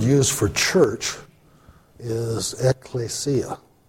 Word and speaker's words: ekklesia: Klesia, that K-L-E there, that's it. The first E is an epsilon, the second E is an eta. You're hearing the words, usually in alphabets, they name the word after the way you pronounce it. So, you know, the second E as an eta is pronounced as an A. So it's klesia, you know, which means to call.